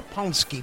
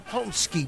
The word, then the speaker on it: paczki: Pomsky box. The Pomsky box. It's spelled P-A-C-Z-K-I. Yeah, it's Polish.